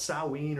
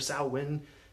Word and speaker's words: Samhain: And if you ever read it, it's pronounced Samhain or Samhain, and it looks like it reads Samhain. And that's because the Irish literally decided we want our letters to have a completely different sound than everyone else's.